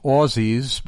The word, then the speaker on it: Aussie: It's softer. So you do it the same way that James White does, and I do try and correct him regularly. It's not worked. Aussie, it's softer.